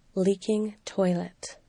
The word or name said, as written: leaking toilet